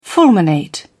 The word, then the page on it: fulminate: British English